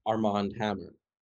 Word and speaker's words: Armand Hammer: His father, also named Armie Hammer, full name Armand Hammer, which is, say it, you pronounce it differently, it's Arm and Hammer, like the what is that, like it's some baking soda or baking powder.